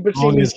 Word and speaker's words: Buscemi: Buschetti, the guy I always pronounce his name wrong from Boardwalk Empire. Buschetti. Yeah, gotta get his name right. We're butchering the shit out of his name. – It's Buscemi.